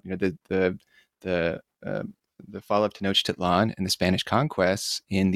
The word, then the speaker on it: Tenochtitlan: Before we get started, is Tenochtitlan. That's how we pronounce that, correct? – Well, actually, nobody knows exactly how it was pronounced, so don't worry about it. And of course, Tenochtitlan is quite good.